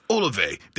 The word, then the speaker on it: Olave: Let's go with Chris Olave.